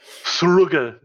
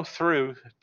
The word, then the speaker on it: through: Thrugga. No, through. T-H-R-U. Sure.